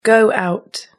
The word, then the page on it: go out: British English